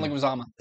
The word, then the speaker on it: Leguizamo: I hate to butcher his John Leguizamo.